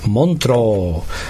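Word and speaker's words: Montreux: Tak, město ve Švýcarsku se čte Montro, stejná výslovnost jako u Bordeaux.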